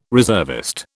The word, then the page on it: reservist: British English